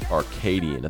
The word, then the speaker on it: Arcadian: We are here to talk about the movie Arcadian.